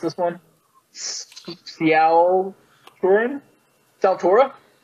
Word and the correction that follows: Sepultura: something like that. – Sepultura. – Yeah, Sepultura, that's it. Opeth, and then Lucana Coil. – Otep. Otep. O-T-E-P? – Yeah. Oh, no, O-P-E-T-H.